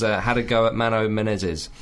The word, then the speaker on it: Menezes: Menezes.